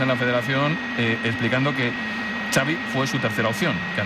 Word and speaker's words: Xavi: Xavi.